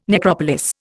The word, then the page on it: necropolis: Indian